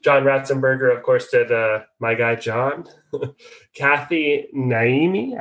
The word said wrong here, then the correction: Najimi: I am not sure if I'm saying that right. – Najimi.